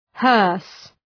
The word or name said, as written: hearse